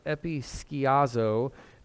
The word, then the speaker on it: episkiazo: It's pronounced episkiazo, and that is what we translate overshadow.